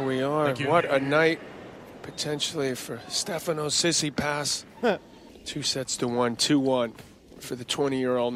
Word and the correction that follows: Tsitsipas: This is how you pronounce his name. – I'm Stefano Tsitsipas and I'm from Greece. – Oh, from Greece! Great way to put it. 1-3-3-5-3.